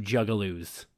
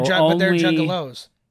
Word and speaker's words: juggalos: I want to be called Tebow the gigaloo because I only do juggaloos. – But they're juggalos. – Yeah. – Oh, I get it. You just wanted to mispronounce it.